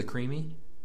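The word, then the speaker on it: Creeme: You've been to the Creamy? – I don't think so.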